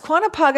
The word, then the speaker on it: Quantipog: Lake Quantipog. – Oh, I don't think I'm saying it right. It could be Quantipog. – No, it's Quantipog.